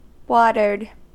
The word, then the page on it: watered: American English